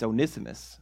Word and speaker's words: Onesimus: I don't know why I went back to the way I used to pronounce it. It's Onesimus. That's the correct way to pronounce it. And for the longest time, I would pronounce it Onesimus, and I thought that's a weird, you know.